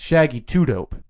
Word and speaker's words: Shaggy 2 Dope: No, it's Shaggy 2 Dope. – Shaggy 2 Dope. – Yeah. – I put the emphasis on the wrong syllable. – Yeah, you got, because he's too dope.